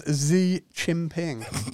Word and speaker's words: Xi Jinping: I don't think you're saying that right. – Big up him, man. – Sorry if I got the pronunciation wrong. Well, I guess we're both not allowed in China now. – I've got. – You said his name's Xi Jinping. – Xi Jinping. – Jinping. – Where's XI? How do you pronounce XI? C.